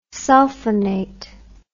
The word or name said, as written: sulphonate